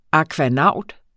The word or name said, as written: akvanaut